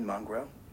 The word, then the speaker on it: Mungro: My name is Raymond Mongro. My last name is spelled M-U-N-G-R-O.